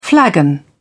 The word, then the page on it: flagon: British English